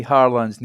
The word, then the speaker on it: Haaland: And so I was going to quickly ask you, how do you say Haaland's name properly, if you don't mind? – Haaland. – Sorry. So Haaland and Haaland.